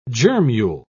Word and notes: germule: British English

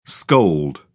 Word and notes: scold: British English

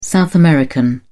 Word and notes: South American: British English

